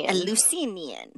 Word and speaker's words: Eleusinian: which was used in the Eleusinian. Is that how you say it? – Eleusinian.